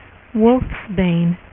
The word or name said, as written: wolfsbane